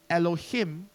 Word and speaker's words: Elohim: He's known as Elohim. Elohim, just in case you don't know how to pronounce that, is that Elohim? It's Elohim, and it means God, and it refers to God's power and might, as in he is the only supreme and true God.